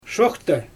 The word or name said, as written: ʃoxta